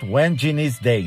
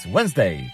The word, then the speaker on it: Wednesday: No, Pastor Antal, it's not when Genie's day. No, no, no, no. It's Wednesday. Just two syllables. Wednesday. Wednesday, June 15th.